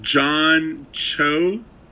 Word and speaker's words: John Cho: It's with John Cho...